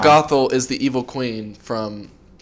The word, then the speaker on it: Gothel: G-O-T-H-E-L. Gothel. – Gothel? – Gothel.